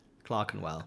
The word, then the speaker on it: Clerkenwell: I don't know, kind of like the walkability of Clerkenwell. – Clerkenwell. – Oh, is that how you pronounce it? – Yeah.